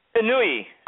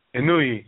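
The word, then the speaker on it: Inoue: Inui. – Inui, yeah.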